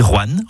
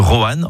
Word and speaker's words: Rouen: Vous avez remarqué comment je dis Rouen en une syllabe plutôt que Rouen en 2 syllabes ? C'est comme ça qu'on y dit dans la région.